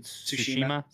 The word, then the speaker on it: Tsushima: I think Ghost of Tsushima. How do you say it? – Tsushima.